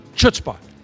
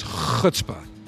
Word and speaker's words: chutzpah: and she stumbled and said, chutzpah. No, it's chutzpah. Use the Yiddish correctly.